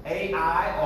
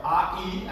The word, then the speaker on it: Ai: But this is Bethel, and Ai, or Ai, actually is what it's supposed to be pronounced.